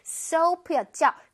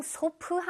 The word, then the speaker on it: soap: Soap였죠. 그냥 소프 하면 절대 안 돼요. 소프 하면은 아무도 못 알아들어요. Soap. Soap. 오케이, 입을 슝 내밀어서 Soap.